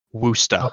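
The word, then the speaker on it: Worcestershire: You read that and you will think, and when I tell you how it's pronounced, you will not believe me. Woosta. – Okay. Oh, God. Yeah. I can't say that. – Thunder will agree with me. It's the city in England, but without the Shire.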